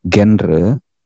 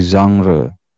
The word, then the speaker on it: genre: Karena itu dalam bahasa Prancis bacanya itu bukan genre ya tapi genre.